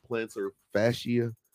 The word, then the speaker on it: Plantar fascia: Tony Pollard, how do you pronounce this? Plantar fascia? – I know it's a foot injury because I know. I wanted to say the real word, though. Plantar fascia. That's it.